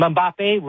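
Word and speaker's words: Mbappé: They have the golden boy, Haaland, and Mbappe rhymes with Poppy.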